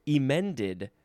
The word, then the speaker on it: emend: To correct, usually by textual alterations, as in emended the manuscript. And I'm emphasizing the E because, well, for one thing, that's how it's pronounced.